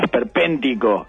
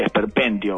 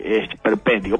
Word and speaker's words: esperpéntico: ningún tipo de giro, bueno, esperpéntico, ahí sí, hoy lo dije bien, quería recuperarme de ayer, que ayer dije esperpéntico o algo así, esperpéntico.